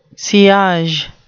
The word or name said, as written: sillage